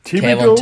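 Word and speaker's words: Thibodeaux: Ähm, fangen wir mal kurz an mit den Pass Rusher, äh, Defense End, Aiden Hutchinson, Kevin Tobo, Thibaut wie, wie spricht man ihn aus? Thibodeau. – Thibodeau. Kevin Thibodeau.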